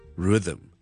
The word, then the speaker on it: rhythm: 有听众问小叔一个好难嘅字嘅发音，呢个字咧大家都识噶啦，系节奏 rhythm, rhythm, rhythm, rhythm. It's a very difficult word to pronounce because of the sound in the middle.